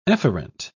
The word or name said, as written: efferent